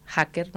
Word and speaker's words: hacker: Por ejemplo, había un alumno que quería estudiar italiano porque decía que los mejores hacker, se dice en italiano, no pronuncian la H, hacker, ¿no?, eran italianos.